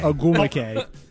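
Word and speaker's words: Nneka Ogwumike: Well, okay, well, let's listen to one of the players, and I can't say her name, but I think it's Ninke Ogu Mikey. – Oh, my Lord. – Save that. Anybody- her name is Ninke Ogumike. – Ogumike.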